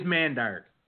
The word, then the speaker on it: Mandark: It is Mandark.